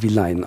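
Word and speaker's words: Villány: Wie schreibt man das, damit man das recherchieren kann? Es schreibt man Vilani. Ah, ähm, Vilani. Ähm, ja, mit Y am Ende und am Anfang mit V. Ansonsten, wie man es also, spricht, Vilani. Vilani. Und man spricht es Vilein aus.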